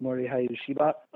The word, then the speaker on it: Morihei Ueshiba: Uh, most people pronounce the gentleman's name wrong, mm-hmm. but Morihei Ueshiba.